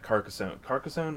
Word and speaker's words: Carcassonne: The coolest thing to me, so we had heard rumors, or they had already confirmed that Carcassonne, is that how you say it? – Yes.